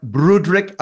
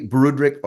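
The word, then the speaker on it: Broodryk: I hope I've pronounced the name correctly. Broederick or Broederick from South Africa. Before I go any further, have I got the name right? Is it Broederick? – That's close enough. Well done for a foreigner.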